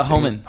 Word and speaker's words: Hohmann: Uh, Hohmann.